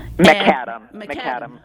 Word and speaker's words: McAdam: McAdam, like the road.